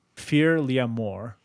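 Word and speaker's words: Fear Liathmor: Fear L'Amour. Ugh. But it's spelled weird. It's like, well, fear is normal. And then L-I-A-T-H-M-O-R. – Hmm.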